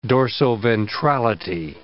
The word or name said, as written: dorsoventrality